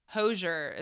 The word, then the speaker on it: Hozier: H-O-I-Z-E-R. Is it Hoiser? You know, he did Take Me to Church. – Oh, Hozier is how I've always said it.